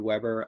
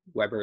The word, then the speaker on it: Weber: Yeah, so they can find me on Instagram at David Weber underscore passionate. So it's Weber is spelled W E B E R.